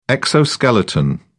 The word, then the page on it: exoskeleton: British English